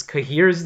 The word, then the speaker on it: Cahir: So they end up with, like, a weird kind of situation where, like, for instance, Kahir's name was not translated. Like, a C will never be pronounced as a K in Lithuanian, but he's still given as C-A-H-I-R.